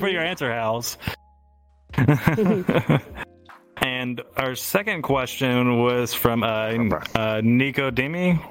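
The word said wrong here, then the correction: Nicodème: which I might have pronounced that wrong. – Nicodeme, yeah, that's yeah, I'm not that got me a little bit.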